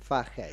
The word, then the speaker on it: Fahey: Fahey Fajey.